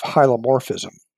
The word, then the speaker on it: hylomorphism: But the perspective that Descartes cast aside was that of hylomorphism, which is Slow down. What was it called again? – Oh, sure. Hylomorphism, H-Y-L-O-M-O-R-P-H-I-S-M.